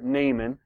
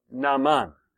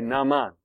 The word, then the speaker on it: Naaman: Now we pronounce it Naaman. Uh, how do you pronounce it? Naman. Okay, Naman. And it's got the two A's, and I think probably in Arabic it would be Naaman, something like that.